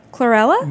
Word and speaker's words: Chlorella: But you also talk about superfoods like fermented vegetables, turmeric, and one that I didn't know, which was the chlorophyll powder, the algae, Cholarella. – Chlorella? – Yes, that's the one. Butchered that well.